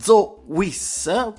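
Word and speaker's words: Zoe Wees: Adesso ci andiamo ad ascoltare la bellissima Zoe Wiss, perché è così che si pronuncia, con la sua I Like Girls Like Us, e adesso ci andiamo in questa bellissima terra di Zoe Wiss.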